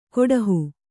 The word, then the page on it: ಕೊಡಹು: Kannada